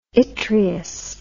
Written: yttrious

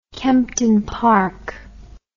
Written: Kempton Park

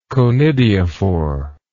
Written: conidiophorous

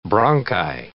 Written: bronchi

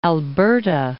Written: Alberta

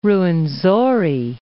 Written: Ruwenzori

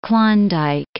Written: Klondike

